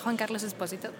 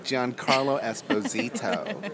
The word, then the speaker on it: Giancarlo Esposito: Juan Carlos Esposito. – I'm like Giancarlo Esposito. – No. – Wow. – Yes, the difference between a Spanish and a non...